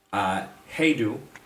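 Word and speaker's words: hej då: The next one is modern word, heidu.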